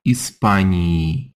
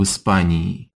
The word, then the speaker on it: Испании: So when we say in Spain, it gets that long e ending. Listen again. В Испании.